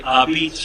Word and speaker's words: apizza: Yeah, they are actually not an homage to New Haven, because in New Haven, you would pronounce it's the word A-P-I-Z-Z-A, beats. And so when you see it's actually Abitz Shoals.